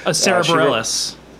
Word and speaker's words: Bareilles: Sarah Bareilles.